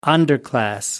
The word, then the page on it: underclass: American English